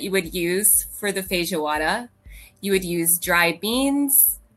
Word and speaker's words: feijoada: Um, so, in fact, I wanted to explain today how to make a, a feijoada. Did I say that correctly? Feijoada. Feijoada. feijoada. – Yes.